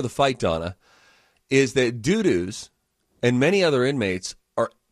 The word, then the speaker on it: Dudus: Or Dudus? It's D-U-D-U-S. We get to choose how we pronounce it. – That's Dudus.